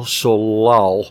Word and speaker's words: Solal: An incredible jazz soundtrack composed by Marshall Solal. I'm going to spell that. And Marshall, like the word Marshall. And S-O-L-A-L, a French cat that even played with Django Reinhardt.